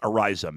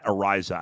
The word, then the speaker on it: Araiza: I think his last name is pronounced Araiza. Matt Araiza, I believe, is how you say his name.